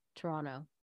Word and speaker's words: Toronto: I think it's like remembering when I first saw the show in Toronto, actually, they don't pronounce the T there, Toronto.